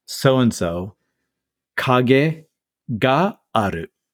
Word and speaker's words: Kage: Wait, so what's the second syllable? Is it a G? – K-A-G-E. Kage. – Oh, okay, got it. So there's no T at the end.